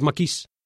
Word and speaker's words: maquis: Recuerdo la desbordante animación de los maquis, pronunciado a la francesa con acento en la I, que anticipé hace unos días.